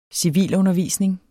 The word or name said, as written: civilundervisning